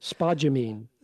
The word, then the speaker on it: spodumene: So Piedmont Lithium's website, says, quote, we believe, is it spodumene? Is that how you say it? – Spodumene. – Okay. Spodumene. We